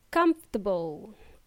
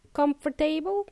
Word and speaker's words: comfortable: Comfortable or uncomfortable. Cómodo or incómodo. So it's not pronounced as comfortable. Comfortable? No. It's comfortable.